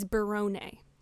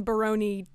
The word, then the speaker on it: Barone: And apparently it is Barone, which is a lot better than Barone to rhyme with macaroni, I have to say, and it does make some more sense.